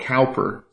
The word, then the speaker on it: Cowper: It's spelled Cowper, C-O-W-P-E-R, but his name was Cowper.